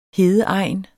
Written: hedeegn

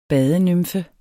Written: badenymfe

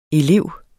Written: elev

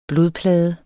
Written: blodplade

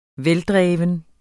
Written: veldreven

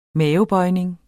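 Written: mavebøjning

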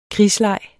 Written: krigsleg

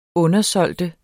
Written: undersælge

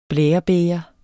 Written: blærebæger

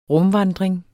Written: RUMVANDRING

